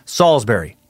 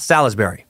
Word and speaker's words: Salisbury: Salisbury is pronounced Salisbury, not Salisbury.